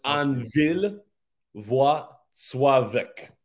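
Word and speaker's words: Anwil Włocławek: So the W in Polish sounds like a V, and when you see an L, it sounds like a W. So it's Anvil Wacławek.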